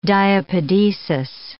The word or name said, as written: diapedesis